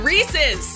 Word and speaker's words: Reese's: Snickers, Baby Ruth, Reese's Fast Break. Did I pronounce that right? – Oh, my God. Reese's.